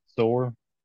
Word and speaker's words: Zoar: Usually when you see a TS together, it's pronounced like a Z, kind of. So it's Zor.